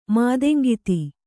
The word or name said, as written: ಮಾದೆಂಗಿತಿ